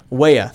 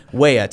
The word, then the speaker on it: Weah: That was finished off by Tim Ray. I can't remember how you say his name. – Weah. – Thank you. I knew it was like Raya or something like that. Weah.